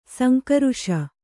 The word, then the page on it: ಸಂಘರ್ಷ: sangharṣa